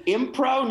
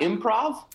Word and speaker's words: impro: Did you say impro, not improv? – Yeah, that's what we say. We say impro in this country. It's another... – Yeah, the V is silent.